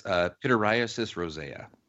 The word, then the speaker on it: pityriasis rosea: I did flub the pronunciation, I'll have to admit. – Well, didn't you say roseola too? – It's pityriasis rosea. – Rosea. – Okay.